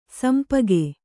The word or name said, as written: ಶಂಪಾಕ